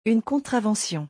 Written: une contravention

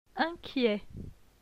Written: Inquiet

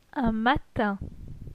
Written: Un matin

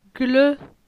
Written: Gle

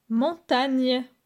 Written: Montagne

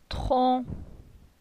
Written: Tron

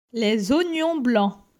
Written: Les oignons blanc

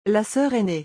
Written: La soeur ainee

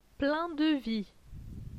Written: plein de vie

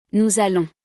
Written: nous allons

